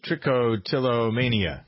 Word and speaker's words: trichotillomania: Molly is in Green Bay with what she thinks is the definition of how did you pronounce it? Trichotillomania. – That was great.